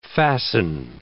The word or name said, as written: fasten